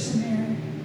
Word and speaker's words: Smyrna: Can you read the Church of Smyrna? How do you say that? Yes. Smyrna. Well, then go ahead, because that note was on my heart for you to read that.